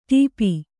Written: ಟೋಪು

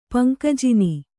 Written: ಪಂಕಜಿನಿ

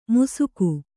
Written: ಮುಸುಗು

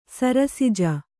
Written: ಸರಸಿಜ

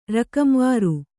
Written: ರಕಂವಾರು

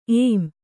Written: ಏಂ

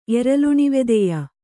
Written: ಎರಲುಣಿವೆದೆಯ

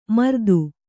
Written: ಮೂರುತಿ

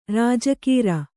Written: ರಾಜಗೀರೆ

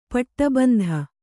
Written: ಪಟ್ಟಬಂಧ

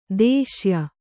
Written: ದೇಶೀಯ

